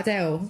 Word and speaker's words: Adele: He came and asked me how I say my name, and I was like, Adele.